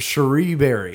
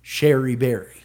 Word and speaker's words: Cherie Berry: Cherie Berry. – Cherie Berry. – The funny thing is everybody thinks it's pronounced Sherry Berry. – Because that would make sense. – But it's not.